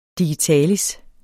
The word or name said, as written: digitalis